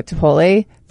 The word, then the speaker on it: Chipotle: Well, no, and that was kind of funny because on Saturday night, we ended up going to Chipotle for, did I say it right? – Yeah, you did.